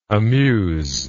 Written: amuser

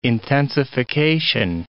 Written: intensification

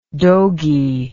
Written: dogey

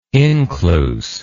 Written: incluse

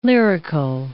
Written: lyrical

